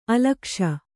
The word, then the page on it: ಅಲಕ್ಷ: alakṣa